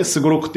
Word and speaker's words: 3569: あれ使って、もうなんかね、す、すご、 3569 っていうライブのタイトルで、すごろくって読ませるんですよ。